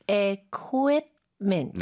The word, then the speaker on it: equipment: 오, U 쪽에 강세가 많이 가고요. Quip이 아니라 쿠 입을 다 발음을 하는데 부드럽게 하는 거죠, 그렇죠? – Yes. – Right. So 이 한 번만 더 들려주세요. – Equipment.